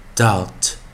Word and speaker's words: doubt: 那注意啊，O U B T 的时候 B 不发音，out out out。上次教过的生词有 doubt。